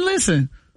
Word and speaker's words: listen: Listen is another one where the English words has a silent letter. – Silent and listen. – T. Listen. You ain't no T in listen.